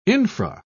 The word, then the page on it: infra: British English